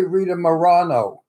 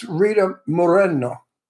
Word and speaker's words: Rita Moreno: People say Rita Morano or Marino or Moreno. It's it's Rita Moreno. – That's how you say it in Spanish.